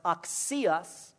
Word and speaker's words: axios: Well, this word uh, worthy, an interesting Greek word, A X I O S, it's, it's pronounced oxios, oxios, axiom, oxios.